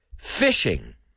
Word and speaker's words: phishing: Okay, this phishing is written with a PH, like telephone, right? And it's P-H-I-S-H-I-N-G, phishing, phishing. Now,